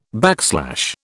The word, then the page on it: backslash: British English